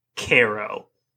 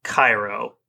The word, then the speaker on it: Cairo: Cairo, Illinois is in fact pronounced Cairo, not Cairo, like the city in Egypt. So if you see it on paper, it doesn't sound the way you think it does, probably.